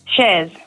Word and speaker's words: Chers: Shers. – Shers, because girls here have written your name as C-H-E-R-S.